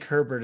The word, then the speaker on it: Herbert: Who is Eber? – Who is Frank Herbert is the correct answer. – Herbert? – I'm sorry. – I've never actually seen it. I was thinking H-E-R-B-E-R-T and I just mispronounced it.